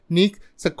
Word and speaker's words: Pnyx: นิกส์สะกดด้วย PNYX PN เนี่ยเป็นอักษรควบไม่ออกเสียงตัว P